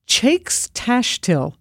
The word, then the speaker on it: Teacs Taistil: Chaix Taistil mm-hmm. is the closest I can get to it, because mm-hmm. in, you know, good Gaelic language, it's spelled TEACS TAISTIL.